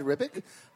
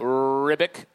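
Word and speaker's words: Ribić: Is that how you say it? – Yeah. – Ribic. – Is it? – That's how I remember it. – Yeah. It's you just think of Chronicles of Riddick.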